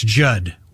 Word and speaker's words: Judd: That's Judd with two D's. The second D is silent.